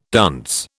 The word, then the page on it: dunce: British English